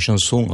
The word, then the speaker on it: Chamson: Comment vous dites Chanson, Chamson, Chanson? – On dit André Chanson.